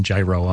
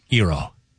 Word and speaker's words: gyro: Because I was doing gyro on purpose to just be a jerk. But it's Eero.